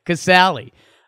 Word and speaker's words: Casali: Curt Casali. I don't even know if I'm saying that right. Yeah, Casale.